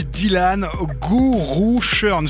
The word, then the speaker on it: Dylan Gooroochurn: Et je suis ravi d'accueillir dans DMA aujourd'hui Dylan Gourouchern.